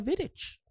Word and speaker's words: Vidic: Nemanja Fidik is Nemanja Vidic.